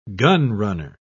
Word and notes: gunrunner: British English

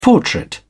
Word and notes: portrait: British English

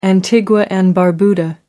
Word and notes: Antigua and Barbuda: American English

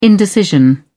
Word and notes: indecision: British English

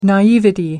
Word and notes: naivety: American English

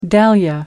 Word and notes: dahlia: American English

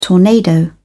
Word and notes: tornado: British English

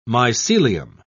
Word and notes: mycelium: British English

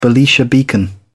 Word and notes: belisha beacon: British English

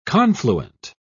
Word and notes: confluent: British English

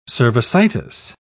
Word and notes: cervicitis: British English

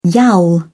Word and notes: yowl: British English

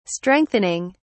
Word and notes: strengthening: British English

